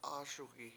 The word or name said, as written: AASugi7